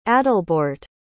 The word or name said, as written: adalbeorht